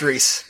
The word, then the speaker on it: Rhys: I think it's pronounced Reese, but we'll go with Rhys.